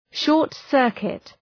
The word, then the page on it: short-circuit: ,ʃɔ:rt’sɜ:rkıt